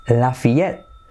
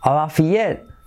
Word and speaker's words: Lafayette: ¿Y cómo se pronuncia? Lafayette, Lafayette, Lafayette, all right, Lafayette, yes, I live in Lafayette.